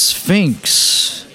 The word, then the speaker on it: Sphinx: It's pronounced Sphinx. Zero is silent, where you would say the zero, leave space for a zero.